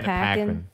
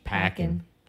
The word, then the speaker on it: Paquin: It's Anna Paquin. – Paquin? – She's Paquin.